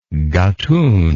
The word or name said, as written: Gatun